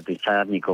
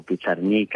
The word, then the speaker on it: Pizarnik: Naturalmente, Alejandra Pizarnik o Pizarnik, a seconda della pronuncia che danno a volte in Argentina,